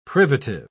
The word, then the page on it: privative: English; British English